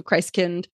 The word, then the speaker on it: Christkind: Martin Luther first suggested the, quote, Christkind, which is like, it looks like it's spelled Christkind, but I think it's like German, so Christkind, as the bringer of gifts.